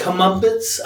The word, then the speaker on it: comeuppance: Comeuppance? How do you say it? Comeuppance? – Yeah, comeuppance. Yeah.